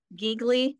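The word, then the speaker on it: Gigli: Also, the film, I believe it's pronounced Geegly, in 2003 with Jennifer Lopez portraying Ricky. – Oh, is that Geely? – Oh, God.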